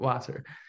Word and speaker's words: Wasser: Wasser.